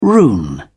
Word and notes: rune: British English

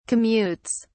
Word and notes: commutes: British English

